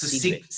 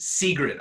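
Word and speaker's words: Sigrit: It sounds like the word secret. I think it's kind of pronounced like that, right? It's like S-I-K-R-I-T, I think. – I think it's S-I-G-R-I-T. I think it's secret.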